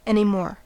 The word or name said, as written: anymore